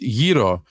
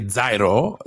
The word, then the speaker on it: gyro: It is pronounced Yiro because you people who say Zairo don't know the Greek, okay? Me and Yanis will tell you. It's Yiro. Why? Because gyro means around.